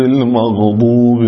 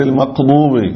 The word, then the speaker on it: المغضوب: المغضوب عليهم ولا الضالين بعضهم يقول غيري غير المغضوب غير المقضوب هذا بعضهم يقلب القاف غين قوري يقول مثلا ولهذا يكتبوها هم بالفارسية آقا بالقاف بس تنطق آغا حجاغا لاحظ نعم.